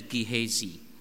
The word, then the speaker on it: Gehazi: And he said to Gehazi, I tried to look up the pronunciation in a book for this name, and It says to pronounce it as Gehazi.